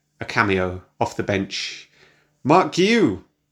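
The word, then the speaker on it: Guiu: I mean, I've heard Mark Giu, but I think it's Mark Giu. Essentially, it's Giu. Mark Giu.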